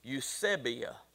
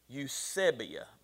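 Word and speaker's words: Eusebia (εὐσέβεια): If you use the Strong's Concordance, it's number 2150, and it is this Greek word, and the pronunciation is out beside it, Eusebia. Eusebia.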